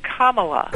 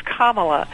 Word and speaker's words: Kamala: We're supposed to say Kamala. Vice President Biden actually mispronounced her name yesterday. – Yes, he did. – But it's Kamala.